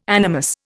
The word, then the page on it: animus: Indian